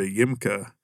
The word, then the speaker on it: Yimka: And so that was my freshman year, and we even got to stay in, it's called Casa de Yimka. So if you like phonetically say YMCA Yimka, Casa de Yimka, it was actually a place this was in Tijuana, Mexico.